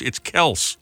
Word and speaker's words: Kelce: It's Kelce with an S sound, not a Z at the end.